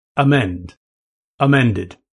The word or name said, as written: amend